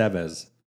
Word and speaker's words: Estevez: Am I saying it right? Estevez. – Mm-hmm. Estevez. – Yeah. Wrong emphasis on the wrong syllable there.